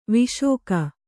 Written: ವಿಶಾಖ

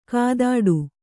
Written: ಕೈತಟ್ಟು

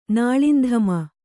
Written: ನಾಳಿಂಧಮ

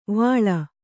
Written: ವಳಿ

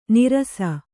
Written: ನೀರಸ

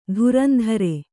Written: ಧುರಂಧುರಿ

